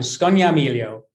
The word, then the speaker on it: Scognamiglio: Scognamiglio.